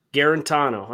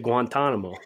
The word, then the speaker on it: Guarantano: How do you say his last name? – Guarantano. – I almost said Guantanamo. Guarantano from Tennessee, and this Temple kid, which is a great segue into our first column on the tier maker.